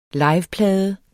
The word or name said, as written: liveplade